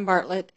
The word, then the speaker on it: Bartlett: My name is Joanne Bartlett, and it's spelled B-A-R-T-L-E-T-T.